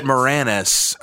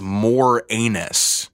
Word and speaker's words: Moranis: Now, that pronunciation of the last name. I've always said it Moranis, and yeah, I feel like too. Specifically because I think that's how he pronounces it, and I gotta assume the reason behind that being he's pronouncing his last name not as more anus.